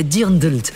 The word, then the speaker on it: Dirndl: Et puis on a parlé de la culotte en cuir pour les garçons, le Lederhosen, et chez les filles, c'est le Dirndl. Et je vous promets que c'est comme ça qu'on dit. Ça s'écrit D-I-R-N. NDL.